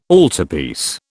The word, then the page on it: altarpiece: British English